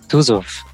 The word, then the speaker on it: Tuzov: How do you say your last name, Pavel? – It's Tuzov.